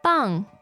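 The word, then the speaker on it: 磅: How do you say pound? – Bang. – Bang.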